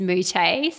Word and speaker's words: Moutet: So my maiden name is Moutet. So it's M-O-U-T-T-E-T. It's French. It was unpronounceable.